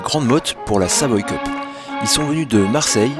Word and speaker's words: Savoy Cup: Est-ce que tu peux m'aider déjà à le prononcer correctement Alors, on dit la Savoy Cup. Savoy Cup.